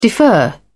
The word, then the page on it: defer: British English